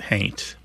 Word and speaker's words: haint: somebody might spell it H-A-U-N-T, but pronounce it haint.